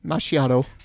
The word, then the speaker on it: macchiato: Say it correctly. Macchiato.